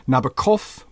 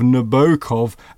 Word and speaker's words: Nabokov: My New England ear is not offended by the long, elegant middle O of Nabokov, as delivered in American academies.